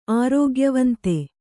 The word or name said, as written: ಆರೋಗ್ಯವಂತೆ